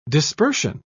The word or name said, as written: dispersion